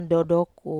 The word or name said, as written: dodoku